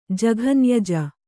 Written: ಜಘನ್ಯಜ

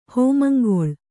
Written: ಹೋಮಂಗೊಳ್

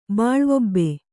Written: ಬಾಳ್ವೊಬ್ಬೆ

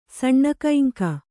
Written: ಸಣ್ಣಕೈಂಕ

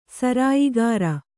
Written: ಸರಾಯಿಗಾರ

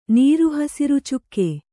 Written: ನೀರುಹಸಿರುಚುಕ್ಕೆ

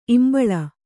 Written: ಈಂಬುಳಿ

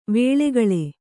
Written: ವಲ್ಕಲ

